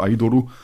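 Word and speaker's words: Aidoru (アイドル): Und es gibt einen japanischen Begriff, der heißt Aidoru, also Aidoru, geschrieben, so wie eben ein Japaner das schreiben und dann aussprechen würde, Aidoru.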